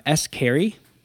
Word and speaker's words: S. Carey: I listened to another one by S. Carey. Have you ever heard of him? – No. He sounds is, like scary, but like mispronounced.